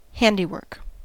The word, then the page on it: handiwork: American English